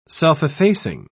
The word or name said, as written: self-effacing